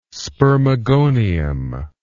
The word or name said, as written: spermagonium